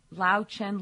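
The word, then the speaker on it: Löwchen: It's Lao Chen, little lion in German. It means little lion.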